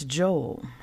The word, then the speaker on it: Joel: So I had to check and make sure that I knew how to pronounce this prophet's name. And it's Joel.